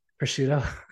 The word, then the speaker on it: prosciutto: How do you say it? Prosciutto. – Prosciutto.